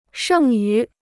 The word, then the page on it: 剩余: shèng yú